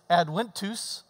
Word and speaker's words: Adventus: which in Latin, it's Adventus, because the V's are pronounced W.